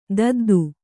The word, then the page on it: ದೊದ್ದೆ: dodde